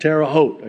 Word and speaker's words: Terre Haute: Last time I totally mispronounced that. Terre Haute. I just want you to know I know how to pronounce it now.